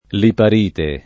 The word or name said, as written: liparite